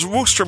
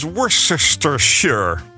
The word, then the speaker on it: Worcestershire: Maar hoe komt het dan dat we het als Worcester moeten uitspreken en niet als Worcestershire?